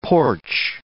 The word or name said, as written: porch